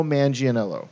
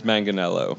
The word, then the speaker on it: Manganiello: Come on, let's see who can actually say his name right. – Joe Manganiello. – Nope. – Shit. Joe Manganiello? – Closer. It's Manganiello. – Manganiello. – Manganiello. – Oh, that's right.